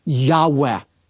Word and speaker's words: Yahweh: It's known as the Tetragrammaton, and most Semitic scholars believe it's pronounced a breathy Yahweh.